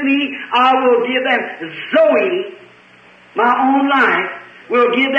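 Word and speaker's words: Zoe (ζωή): And the both Greek words as the life of God or eternal life is pronounced Zoe, Zoe, Zoe life.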